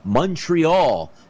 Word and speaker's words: Montreal: Send it back to Montreal as we see if the Canadiens can steal a game or at least win two games.